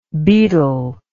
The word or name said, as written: baetylic